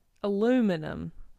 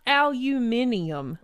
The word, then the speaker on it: aluminum: Not aluminium. – Listen, we understand. – It's spelled that way, for sure. – Yes, and there are people. I feel like a very small group of people. – Well, Europeans. – Yeah. – A big group of people. Right? Well, that's yeah. That's how Europeans pronounce it, right? – Well, whoever pronounces it. – I don't know. – We get it. You know how it's spelled. It's aluminum. Just leave it at that.